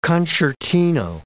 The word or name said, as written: concertino